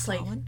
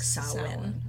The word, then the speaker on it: Samhain: It's Samhain? People usually pronounce it Samhain, because that's how it's pronounced. – Yes. – Or spelled, I should say. But it's usually pronounced like Samhain. – Okay, and the pronunciation I had was S-O-W...